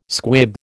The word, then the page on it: squib: American English